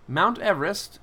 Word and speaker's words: Everest: Mount Everest should probably technically be pronounced Mount Everest after Sir George Everest, the guy who it was named after.